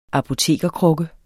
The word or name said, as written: apotekerkrukke